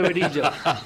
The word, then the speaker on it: verija: Se escribe verija, pero es verilla.